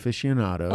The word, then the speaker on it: aficionado: Aficionado.